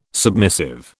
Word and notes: submissive: British English

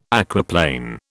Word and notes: aquaplane: British English

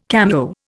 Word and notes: cameo: Indian

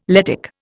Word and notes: Lettic: Indian

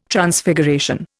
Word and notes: Transfiguration: Indian

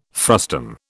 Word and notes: frustum: British English

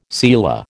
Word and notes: selah: American English

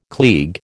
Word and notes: klieg: American English

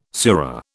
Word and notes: sura: British English

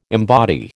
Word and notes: embody: American English

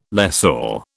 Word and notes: lessor: British English

